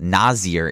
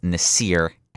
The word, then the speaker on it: Nasir: it's not Nasir, it's Nasir Adderley.